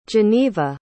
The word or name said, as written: Jeneva